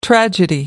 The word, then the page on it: tragedy: American English